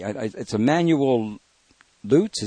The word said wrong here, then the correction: Leutze: is it? – Leutze. – Leutze, thank you very much.